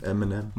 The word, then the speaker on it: Eminem: M&M. Eminem. Marshall Mathers. Mhm. Viel schneller ausgesprochen Eminem.